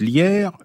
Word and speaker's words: LIER: Alors, on prononce Lierre, mais ça s'écrit L-I-E-R.